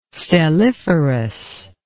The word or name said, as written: stelliferous